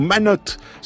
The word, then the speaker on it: Manottes: Merci à Elsa qui nous a écrit aux Manottes. Ça s'écrit M-A-N-O-T, mais elle dit attention, le T se prononce.